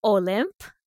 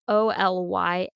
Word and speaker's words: Olympe: It looks like Olympe, like Mount Olympus. It's Olympe. But you do say Olympe, right, Lindsay? – Yeah. Okay. Almost like oil lamp, but like Olympe.